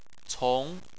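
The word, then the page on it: cong: tone 2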